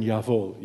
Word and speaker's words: Jawohl: What is the first letter in Jawohl, you Deutsch speakers? What? J, right? J, but you pronounce it as Y, right? And what about Wohl, Jawohl? What is that? That's a W, but it's pronounced as V. So in English, it started to become J-H-V-H, right?